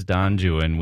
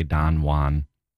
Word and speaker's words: Don Juan: But in the case of Byron and Don Juan, it is Don Juan. We have to pronounce it that way because Byron was writing poetry and it was in meter, and the meter and the rhythms and the rhymes don't work if you say Don Juan.